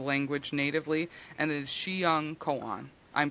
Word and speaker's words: Xiangkhoang: That word is spelled very insanely. It has an X in it. – Oh my God. – But I looked it up and that's how you say it. The spelling is X-I-A-N-G-K-H-O-A-N-G.